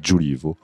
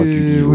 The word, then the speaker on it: Giulivo: Si tu veux vraiment le dire exactement, c'est italien, donc on dit Giulivo. Ah oui, mais tu peux dire Giulivo, oui, ce euh, que tu veux, peu importe.